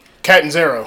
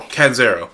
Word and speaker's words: Catanzaro: Catanzaro. – Catanzaro.